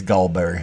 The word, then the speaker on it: gallberry: Let me just say that you're not saying it right because it's a Southern thing. It's gallberry honey.